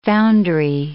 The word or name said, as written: foundry